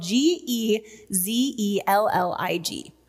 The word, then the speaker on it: gezellig: gezellig. Now, the Dutch hard guttural G makes that word a little difficult to pronounce for us Americans, but it's spelled G E Z E L L I G, gezellig.